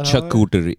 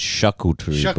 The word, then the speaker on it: charcuterie: Me and Rachel hung out, made a steak dinner for us, made a charcuterie board, and And we had some wine. It's not charcuterie. It's charcuterie.